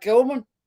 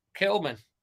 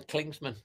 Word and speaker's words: Kilman: Oh, well, the paper talk or on the back, Kilman. – Kilman. You actually said his name right, not the Klingsman, like you normally say.